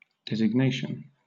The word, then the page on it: designation: British English